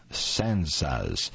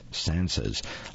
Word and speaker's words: tsantsas: The shirt is decorated with a row of sansas, that's spelled T-S-A-N-T-S-A-S, sansas, lifelike, life-size, and garish, with lips sewn shut and flowing Wonder Woman's hair.